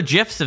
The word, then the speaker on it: GIF: The animated image database Giphy has unveiled the most popular GIFs of 2021. I'm going to pronounce it GIF, by the way, for this entire story. Fight me.